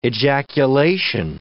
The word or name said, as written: ejaculation